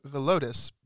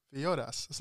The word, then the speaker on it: Villodas: I was pronouncing it Villodas, but JMC on the podcast that I was listening to introduced him as Rich Villoras.